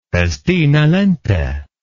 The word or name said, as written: festina lente